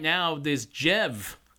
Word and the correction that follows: Djerv: I'm sure I'm not pronouncing that right, but it is D-J-E-R-V. So Jerv.